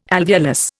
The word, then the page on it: alveolus: Indian